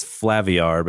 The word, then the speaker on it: Flaviar: So we have this Flaviar. I want to say Flaviar because Flaviar sounds so aggressive. But it's, I think it is Flaviar because it's supposed to be like caviar.